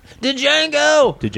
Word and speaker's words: Django: You pronounced it wrong. There's a D. – Django.